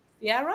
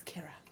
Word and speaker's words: Ciara: no one girl Kira, but it's spelled C-I-A-R-A, and I'm like, Sierra? Yeah. She's like, no, it's Kira.